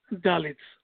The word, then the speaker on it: Dalit: Dalit, or how you pronounce that? D-A-L-I-T-S. – Dalit. – Okay. – That is the word.